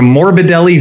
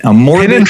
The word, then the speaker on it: Morbidelli: they got the first two syllables perfectly right. It's a Morbidelli V8. A Morbidelli.